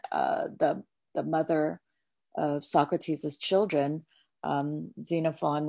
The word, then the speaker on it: Xenophon: yeah, there was a reading on them about Plato said that he'd never earned money from his teachings, whereas I'm sorry, I don't know how to pronounce his name, Xenophon. – I believe, yeah, Xenophon.